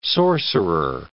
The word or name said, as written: sorcerer